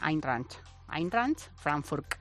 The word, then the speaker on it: Eintracht Frankfurt: Qué fácil de pronunciar, ¿eh? – Sí, sí, sí. Pues Melchor lo dice muy bien. Eintracht Frankfurt.